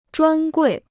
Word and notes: 专柜: zhuān guì

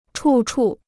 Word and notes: 处处: chù chù

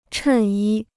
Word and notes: 衬衣: chèn yī

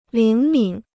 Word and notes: 灵敏: líng mǐn

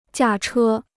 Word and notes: 驾车: jià chē

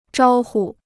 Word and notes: 招呼: zhāo hu

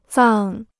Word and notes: 葬: zàng

